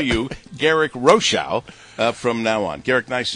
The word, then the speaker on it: Rochow: And yes, Rochow is the way to pronounce that name, even though you've accepted Rochow for a long time.